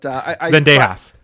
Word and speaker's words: Zendejas: Zendaya, I'm going to mispronounce every single name, but Zendejas.